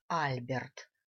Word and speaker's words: Альберт: Представился он как Альберт, с ударением на первый слог.